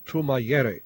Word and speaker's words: Tumayere: it's called Radio Tumayere. I'll spell that T-U-M-A-Y-E-R-E.